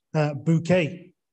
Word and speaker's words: Bouquet: who insists that her name should be pronounced Bouquet.